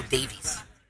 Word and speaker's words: Davies: His name is Alfonso Davies. Not Dave.